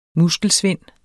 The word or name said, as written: MUSKELSVIND